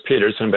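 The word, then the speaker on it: Pedersen: All right, this is you and I are talking to Indy Petterson, P-E-D, not Peterson, Petterson. – Yeah, and it's pronounced Peterson, but it's spelled with a D, which is important on Amazon.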